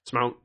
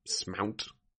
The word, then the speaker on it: Smount: It's weird. It's Irish. – Ah. – That's sort of how it's pronounced. – Smount. – Smount. It was one of those names I translated, and it looked really cool, and then I, like, got the pronunciation, and it's Smount.